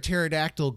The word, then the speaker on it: pterodactyl: Why can't you hear a pterodactyl go to the bathroom? – Why can't you? – Because the P is silent.